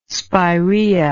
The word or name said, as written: spiraea